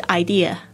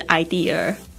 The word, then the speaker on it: idea: 他讲到这个 idea，有很多人念成 idea，他说这个很难听，然后那时候就想说完了，我是不是也念成 idea，我也不知道，但是他说这个应该是 idea 不是 idea， OK， ideas， but no one 这边就是没有人，虽然它不是讲 no people，但是这个 one 就是讲 people or a person 的意思。